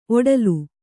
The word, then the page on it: ಒಡಲು: oḍalu